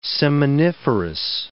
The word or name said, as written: seminiferous